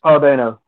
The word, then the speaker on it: Paul Dano: Paul Dano, is that how you pronounce his last name? – Paul Dano, yeah.